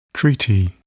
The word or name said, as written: treaty